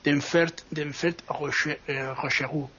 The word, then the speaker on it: Denfert-Rochereau: Hay que también tener en cuenta que la estación, no te lo he dicho mal, ¿no? Es Denfert-Rochereau, ¿no? Que es como se llama.